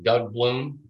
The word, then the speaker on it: Doug Bloom: Doug Bloom, D-O-U-G-B-L-O-O-M.